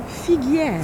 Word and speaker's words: Figuière: Figuière.